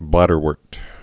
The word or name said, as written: bladderworts